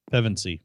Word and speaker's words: Pevensie: Nice job, Girl of Awesomes, LTB00999, and Lucy Pem- Oh, yeah. Pevensie. – Yeah, we, uh, we keep saying that wrong.